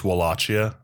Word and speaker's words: Wallachia: Real life Vlad Tepes was a prince of Wallachia, Terra Romanesca. – Uh-huh. Before you add us, it's Wallachia.